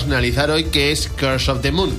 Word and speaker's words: Curse of the Moon: Bueno, bueno, Félix, vamos a este breve análisis del Bloodstain Curse of the Moon. ¿Lo he dicho bien? Eh, Curse of the Moon, sí, así. Curse of the Moon.